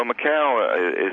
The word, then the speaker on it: Macau: Apparently the world's biggest gamblers are tightening their belts, and the turnover in Macau, I think is the pronunciation, is it not? – Well, Macau